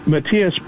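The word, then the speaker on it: Matias: It's Mateus Bombals Hollywood. That's spelled M-A-T-I-A-S B-O-M-B-A-L Hollywood, like the city. One more time, it's M-A-T-I-A-S B-O-M-B-A-L Hollywood, like the city, and I'd be happy to have you join me there for exciting pictures and fun movie facts.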